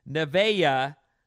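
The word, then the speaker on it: Nevaeh: It's pronounced Nevaya, heaven backwards. I had a student with this name. It doesn't roll off the tongue, but that's how you say it.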